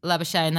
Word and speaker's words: Labuschagne: I like how Labuschagne, he's like gone in there and he's just – Labuschagne? – Labuschagne? – Yeah. – Labuschagne. They said it's like champagne with a labber.